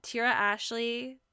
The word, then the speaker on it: Tiera Ashley: You can find me on Instagram at Tiera Ashley, TIERAASHLEY.